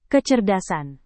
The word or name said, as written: kecerdasan